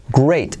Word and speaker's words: great: U como great. Fijaos la R de great como trae una U. Great. She's a great cook. La T de great casi no se dice.